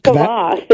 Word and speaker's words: kvass: Kvass.